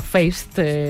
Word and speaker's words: Feist: uno de los clásicos de la guitarrista y cantante canadiense Feist, conocida como Feist, cuando lo pronunciamos tal cual se escribe.